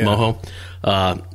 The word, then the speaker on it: mojo: It's spelled mojo, but it's pronounced mojo.